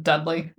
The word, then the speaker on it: Dudley: For clarification, would you just pronounce it Dudley? – Yeah. – Yeah. – Yeah. Just like, not Dudley Dursley.